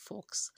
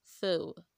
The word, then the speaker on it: faux: Again, F-A-U-X is not pronounced fox. It is pronounced fell.